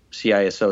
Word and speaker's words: CISO: So the CISO is usually how CISO is pronounced.